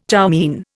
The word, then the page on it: chow mein: Indian